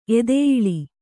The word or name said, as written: ಎದೆಯಿಳಿ